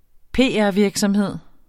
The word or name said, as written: PR